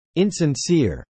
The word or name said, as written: insincere